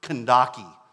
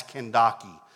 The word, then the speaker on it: Kandake: And actually, technically, that name is pronounced Kandaki. And the newer translations, like the New Living Translation, the NIV, well, they correctly translate that name as Kandake.